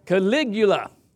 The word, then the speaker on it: Caligula: It's like, okay, better yet, so that you can, did I say that right? Yeah. Okay, praise God. I was going to say Culligan. He's the original Culligan man. Caligula.